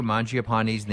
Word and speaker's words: Mangiapane: Okay, this is how to pronounce Andrew Mangiapane's name correctly as pronounced by my Italian producer.